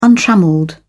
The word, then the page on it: untrammelled: British English